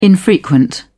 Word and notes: infrequent: British English

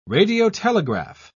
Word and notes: radiotelegraph: British English

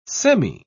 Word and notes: semi-: British English

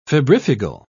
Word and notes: febrifugal: British English

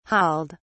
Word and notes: hauled: British English

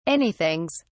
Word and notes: anythings: British English

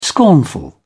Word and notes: scornful: British English

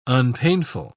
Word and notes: unpainful: British English

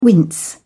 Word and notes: wince: British English

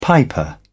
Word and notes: piper: British English

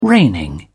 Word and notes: reigning: British English

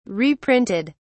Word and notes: reprinted: British English

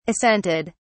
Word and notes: assented: British English

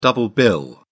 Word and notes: double bill: British English

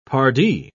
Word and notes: pardi: British English

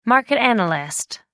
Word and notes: market analyst: American English

